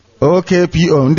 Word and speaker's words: Okpo: Is it Opo or Opo? How is it pronounced? OKPO.